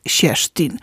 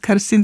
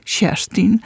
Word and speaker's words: Kerstin: Ostatnio nawet ktoś mi powiedział, że zaskoczyłam tym, że ta Kerstin to jest Sierstin. Że tak się wymawia. Ja się dosyć szybko dowiedziałam. – Też no nie właśnie, wiedziałam. – No właśnie, to nie jest Kerstin, tylko Sierstin.